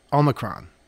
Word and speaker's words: omicron: No, not Omicron. Omicron. – Omicron. – Omicron. – Omicron. – Emphasis on the first syllable.